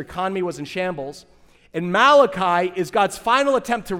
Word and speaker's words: Malachi: They appear in the last book of the Old Testament, Malachi, or as people in New Jersey like to say, Malachi, the Italian prophet, right? Malachi. It's pronounced Malachi.